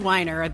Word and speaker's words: Weiner: Matt Wiener slash Weiner? – Yeah. – I think it is Weiner, though that's how he pronounces it anyway.